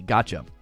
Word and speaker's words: gacha: I pulled this from Wikipedia, so if you're not sure, it's not spelled gotcha, like, haha, I gotcha. It's actually spelled, if you look at the thumbnail or the description of this video, it's spelled G-A-C-H-A, gotcha.